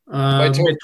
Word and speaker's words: Mitote: Confluences of Solitude, your new book is now out on Mitote Press. I'm probably mispronouncing that too. – Mitote Press. Mitote, yeah.